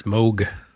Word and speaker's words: Moog: It's Moog.